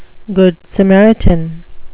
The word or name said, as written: good samaritan